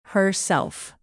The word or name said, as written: herself